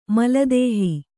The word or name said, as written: ಮಲದೇಹಿ